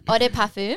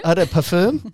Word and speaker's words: Eau de Parfum: Eau de Parfum. – Eau de Parfum.